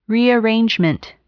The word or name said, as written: rearrangement